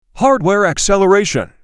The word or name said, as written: Hardware acceleration